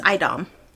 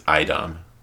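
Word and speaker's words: a'dam: A apostrophe Dom is Idom. Go look it up. It's in the pronunciation guide. – That cannot be right. – He pronounces words in the weirdest ways. It drives me crazy. – You know what? Kyrian? Come on. – That is clearly the Kyrian. It's Idom. It's the very first thing in the glossary. Idom. – Idom? – Yeah. Idom, Damani, and Soldom. Soldom. – Soldom. Yeah, it's like S-U-H-O.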